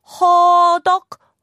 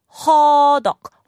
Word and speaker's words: 허덕: It's not ho, it's ha, ha, 허덕, 허덕, 허덕, 허덕. It's kind of a fun word to say there, but it comes out of your throat.